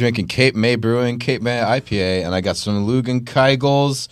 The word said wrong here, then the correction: Leinenkugel: Leinenkugel. – Leinenkugel.